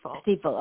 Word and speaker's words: Stiefel: Is it Stiefel? – It's Stiefel.